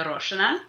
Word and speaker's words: Aroshina: I think you did a great job, so I'm not even going to correct you. I mean, if I was introducing myself in Russian, my given name, I was born in Moscow, it would be Varvara Gennadyevna Aroshina.